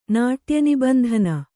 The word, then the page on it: ನಾಟ್ಯನಿಬಂಧನ: Kannada